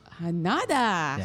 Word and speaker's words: Hanada: I'm Scott Hanada. – Hanada. – Yeah. Scott Hanada. – Spell it with like Canada but with an H instead of a C.